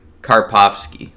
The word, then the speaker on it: Karpovsky: But yeah, another scene like that is with the guy who she's already been letting, this sleazy guy played by Alex Karbop. – Oh God, blooper reel. I have it written down. And I pronounced it earlier. Karpovsky. There we go.